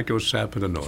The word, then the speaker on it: icosapentaenoic: Icosapentaenoic.